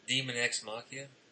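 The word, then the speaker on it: Daemon X Machina: I mean, the uh, is that how you even say it? How do you say it? – Demon X Machina? Isn't that it? – Yeah, that'd be right.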